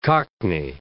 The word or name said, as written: cockney